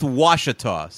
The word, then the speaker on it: Ouachita: It's pronounced Washita, so it's like Wichita.